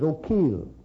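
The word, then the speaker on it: Eochaill: Dun, spelled E-O-C-H-A-I-L, but it's O'Keel. Dun, Canar. How in the world do you get Dun Canar out of C-O-N-C-H-O-B-H-A-I-R?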